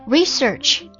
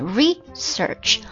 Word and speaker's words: research: Research, 读名词的时候啊，名词的时候它才会重音在前面 research，动词的时候读作 research。